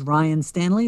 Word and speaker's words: Ryan Stanley: So you can, again, my name is Ryan Stanley. That's R-Y-A-N-S-T-A-N-L-E-Y.